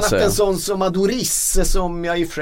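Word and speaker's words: Aduriz: Adorif ska det uttalas enligt de som är väldigt, väldigt kunniga på det här.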